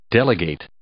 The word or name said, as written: delegate